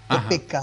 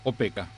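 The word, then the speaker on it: Opeka: Para los oyentes que a lo mejor no fui suficientemente claro, el padre Pedro Opeca se escribe O, P de pato, E, K de kilo, A. Opeca. – Ajá, Opeca. – Pedro Opeca.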